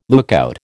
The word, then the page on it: lookout: American English